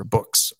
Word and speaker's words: Boox: I don't know, B-O-O, was it box or box? B-O-O-X, or books, I guess is how it's pronounced.